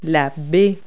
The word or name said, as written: bay